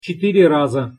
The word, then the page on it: cetyre raza: Russian